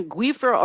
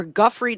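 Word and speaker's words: Giuffre: He cut off his relationship with Jeffrey Epstein because Jeffrey Epstein, quote, stole people that worked in Donald Trump's spa, apparently including Virginia Guifer or Guffrey, depending on who's pronouncing it.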